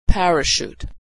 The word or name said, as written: parachute